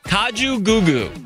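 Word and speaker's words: Kajagoogoo: And I had to look how to pronounce it. Kaju Gugu. – Of course it is. – Kaju Gugu.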